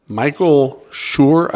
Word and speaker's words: Michael Schur: Which recurring character does The Office writer and producer Michael Schur play on the show? – Go for it right here. You got this, buddy. – For 3 points, not 30. – Say the name again. – Michael Schur.